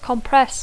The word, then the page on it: compress: English